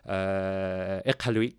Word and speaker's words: Iqaluit: Iqaluit, that's the proper way to say it.